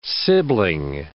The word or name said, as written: sibling